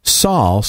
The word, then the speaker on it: Saul: Saul, spelled S-A-U-L, rhymes with Paul,